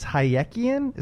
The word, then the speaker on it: Hayekian: and I'm probably going to butcher this, but I think it's Hayekian. Is that right? – That's correct.